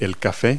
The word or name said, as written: coffee